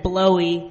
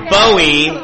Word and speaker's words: Bowie: I said blowy. – That was my first thought. Should have done it. – That was my first thought, too. Yeah. – It's pronounced Bowie.